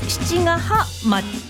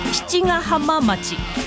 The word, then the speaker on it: 七ヶ浜町: お花がわさびさん、私の住む宮城県には漢数字の 7 に小さい毛 1 個 2個とかのね、毛に浜と書いて七ヶ浜町、七ヶ浜町、漢数字の 7 に小さい毛に宿と書いて七ヶ宿町と読む町があります。小さいこの毛の読み方がガカカカ、漢字や表記や読み方が似ていても所在地が結構離れているので大変です。